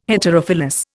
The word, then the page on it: Heterophyllous: Indian